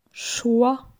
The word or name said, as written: Choix